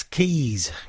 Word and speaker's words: Keese: That's Keys, K-E-E-S-E, but pronounced Keys.